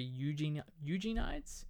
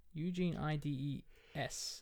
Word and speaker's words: Eugenides: 1993 of the same name by Jeffrey Eugenides. – Okay. – Eugene Ides. I think that's how you pronounce his name.